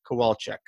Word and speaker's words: Kowalchuck: So I've got with me today, Wes Kowalchuck.